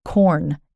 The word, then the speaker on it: Quorn: It's called Qorn, and it's spelled Q-U-O-R-N.